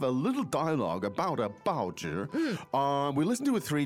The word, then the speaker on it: 报纸: 报纸. 报纸. – 报纸.